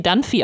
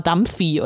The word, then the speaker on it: Dunphy: Und, äh, ich, ich, liebe so, es gibt diese eine Szene, wo, wo äh, Phil ihm versucht klar zu machen, wie Dunphy äh, ausgesprochen, wird. Und, und äh, Jay sagt es halt immer falsch, er sagt immer Dumphy oder so.